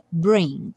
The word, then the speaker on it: bring: Repare que aqui no bring, eu nem pintei a letra G de vermelho, embora ela seja uma consoante oclusiva e normalmente a gente vá pronunciá-la bring bring, ou seja, o G bem sutil. Porém, aqui, como a próxima palavra é it, a gente vai ter bring it, bring it, bring it back, bring it back.